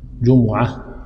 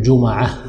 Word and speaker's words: جمعة: الجمعة في اللغة تنطق بضم الجيم وضم الميم جمعة، وأيضا تنطق بضم الجيم وفتح الميم تقول جمعة، واللغة الثالثة بسكون الميم جمعة. ففيها ثلاث لغات: جمعة وجمعة وجمعة، كلها صحيحة.